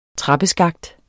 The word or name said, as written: trappeskakt